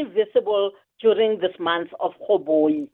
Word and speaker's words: urhoboyi: Yes, Uhoboyi, but it's spelled as in URHOBOYI.